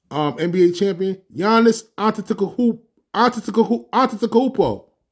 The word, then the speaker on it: Giannis Antetokounmpo: It's like Giannis Antetokounmpo from the Milwaukee Bucks. It took me a year to get his name right. Giannis Antetokounmpo.